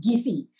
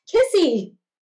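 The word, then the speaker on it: Kissi: You see, DC tribe, but it's K-I-S-S-I, but if you say it like a true Liberian, it's Gissy. But you say it like American, it's Kissy.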